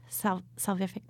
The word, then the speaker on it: salvific: And we're watching this incredible unfolding of God's salvific plan.